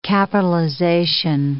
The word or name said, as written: capitalization